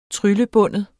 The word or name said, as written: TRYLLEBIND